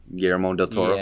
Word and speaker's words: Guillermo del Toro: How do you say Guillermo? Guillermo del Toro? – Yeah.